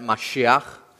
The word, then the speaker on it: Mashiach: We pronounce it underneath that, Mashiach, would be the way the Hebrew would sound.